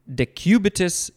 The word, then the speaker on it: decubitus: Called also decubitus ulcer. Sharon, did I say that right? Decubitus? Yeah. Oh, I said it right.